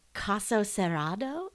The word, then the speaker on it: Caso Cerrado: So there's a show called Caso Cerrado. Am I saying that right? – Wait, Caso? It's like Caso. It's Case Closed. – Yeah. – Great. So there's that show. – It's perfect.